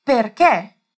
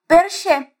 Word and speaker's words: perché: Se aquelas pessoas fossem pronunciar essa palavra aqui, elas diriam perché. Mas agora você já sabe que não é assim, então você vai falar certinho.